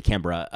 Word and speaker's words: Canberra: Oh, is that how it's pronounced? – They say Canberra.